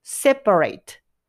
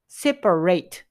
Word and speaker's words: separate: で、動詞の時は、綴りは一緒なんですが、発音がですね、後ろの部分が rate になります。 separate separate になると動詞です。